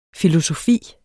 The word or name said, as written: filosofi